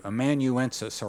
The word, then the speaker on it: amanuensis: How do you say that? Thank you. Amanuensis, or we would call a scribe.